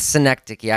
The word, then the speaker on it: Synecdoche: It's Synecdoche. I heard him actually say it in an interview on Creative Screenwriter on their podcast when he was interviewed.